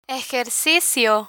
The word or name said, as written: ejercicio